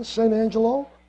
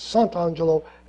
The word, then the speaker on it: Sant'Angelo: So I said, I closed my eyes and I saw my name, Morris John Sant'Angelo. Actually, it's spelled it the Italian way. It's Sant'Angelo, Santangelo, just in case.